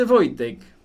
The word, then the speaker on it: Wojtek: Eh, pero en el, día a día cotidiano se dice Wojtek.